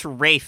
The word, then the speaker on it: Ralph: I think it's Rafe Fiennes.